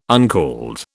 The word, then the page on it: uncalled: British English